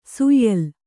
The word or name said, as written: ಶ್ಯಾಲ